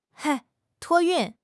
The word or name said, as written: 托运